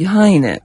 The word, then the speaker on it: Гейне: Гейне, якого німці, до речі, називають Гайне, оскільки комбінація літер «е» та «і» вимовляється як «ай».